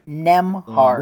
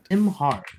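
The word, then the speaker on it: Nembhard: Is it Nebhard or is it just Nebhard? – I don't know. What do you think? – I don't know why you are asking me. I don't know how to pronounce my own name. – Nemhard. This guy's name. B is silent.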